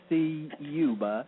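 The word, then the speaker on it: Ciuba: Of course, if you Google my name, if you spell it correctly, you hit every letter in the last name, Siuba. So it's Ted, of course, C-I-U-B, as in Bravo, A.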